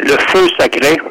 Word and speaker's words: le feu sacré: Yeah, le feu sacré.